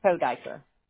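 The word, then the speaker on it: Kodyker: It's K-O-D as in dog, Y-K-E-R, Kodiker.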